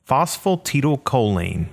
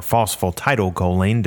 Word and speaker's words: phosphatidylcholine: Phosphatidylcholine, or phosphatidylcholine, depending on how you want to say it, but it's an important type of phospholipid that contains fatty acids, glycerol, and phosphorus.